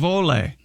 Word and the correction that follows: Hole: But it's pronounced Hole. – Yeah. – Hole? – Detective Hole. Yeah. – It's spelled H-O-L-E.